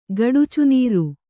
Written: ಗಡುಚುನೀರು

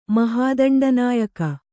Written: ಮಹಾದಂಡನಾಯಕ

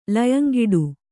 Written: ಲಯಂಗಿಡು

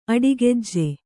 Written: ಅಡಿಗೆಜ್ಜೆ

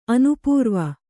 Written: ಆನುಪೂರ್ವಿ

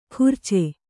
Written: ಕೂರ್ಚೆ

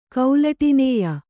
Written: ಕೌಲಟಿನೇಯ

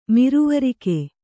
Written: ಮಿರುವರಿಕೆ

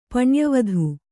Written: ಪಣ್ಯವೀಧಿ

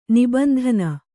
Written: ನಿಬಂಧನೆ

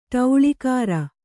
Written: ಠೌಳಿಗಾರ

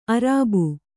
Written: ಅರಬು

